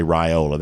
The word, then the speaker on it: Raiola: By the way, I am now saying Raiola, you know, based on your admonition. So I'm going to say Raiola. That's what you say it's supposed to be, how it's supposed to be pronounced.